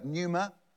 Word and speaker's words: pneuma: So in this verse 8, you've got wind and spirits, the word pneuma, the silent P.